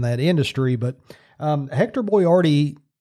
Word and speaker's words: Boiardi: But I think a lot of people probably didn't figure it out because it is spelled so differently than what you're used to hearing. But the man I'm talking about today, his name is Ettore Hector Boiardi, and later in life he would become Chef Boyardee.